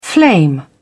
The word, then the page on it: flame: British English